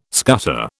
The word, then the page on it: scutter: British English